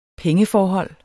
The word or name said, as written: pengeforhold